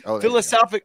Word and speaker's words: philosophically: Philosophically, I always put an extra syllable in the middle of it.